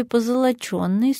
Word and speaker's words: позолоченный: Действительно, «ё» всегда ударное, но в слове «позолоченный» суффикс «ен», поэтому ударение в норме «позолоченный» и только так.